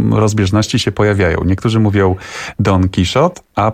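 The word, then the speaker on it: Don Quijote: Rzeczywiście w tradycji polskiej było tak, że tekst dzieła Don Quixota Miguela de Cervantesa trafił do nas z Francji i stąd my byliśmy przyzwyczajeni, żeby używać wymowy francuskiej. Jednakowoż bo oryginał powstał w języku hiszpańskim, więc my jako iberyści cały czas poprawiamy wszystkich, że to jednak jest Don Quixote.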